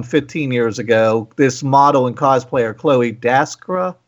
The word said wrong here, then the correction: Dykstra: Dykstra, I think, yeah.